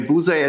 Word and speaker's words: Busey: Wie wird der eigentlich ausgesprochen? Und Gary Busey. Ja, Busey.